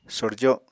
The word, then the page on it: sorjo: Apatani